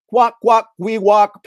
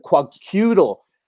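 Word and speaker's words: Kwakwaka'wakw: That is a quack, quack, we walk politician. I used to read about the term, I'd pronounce it quacutal.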